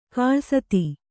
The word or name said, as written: ಖಾಲಸಾತಿ